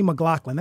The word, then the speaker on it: McLaughlin: So, you know, Sydney McLaughlin, that's how you pronounce her last name, right? – Yes.